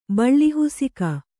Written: ಬಳ್ಳಿಹುಸಿಕ

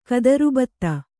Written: ಗೋತ್ರಭಿತ್ತು

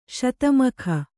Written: ಶತಮಖ

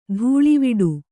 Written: ದಳವಾಡ

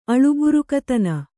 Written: ಅಳುಬುರುಕತನ

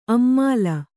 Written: ಅಮ್ಮಾಲ

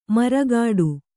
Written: ಮರಗೋಡು

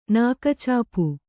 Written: ನಾಕ್ಚಾಪ್

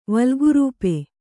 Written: ವಲ್ಗುರೂಪೆ

